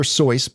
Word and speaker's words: Seuss: It's technically supposed to be pronounced Dr. Seuss, but I'll say Dr. Seuss because everyone does.